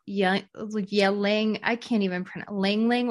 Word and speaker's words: ylang: Well, I would say that jasmine oil, Ling Ling oil, but it's spelled Y-L-A-N-G oil.